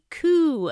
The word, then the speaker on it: coup: Looked to me like a coop attempt. Oops, sorry, I mispronounced that. I meant a coup attempt.